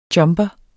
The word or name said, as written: jumper